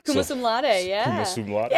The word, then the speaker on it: summa cum laude: Not only that, she graduated summa laude. Is that how you say it? – Summa cum laude.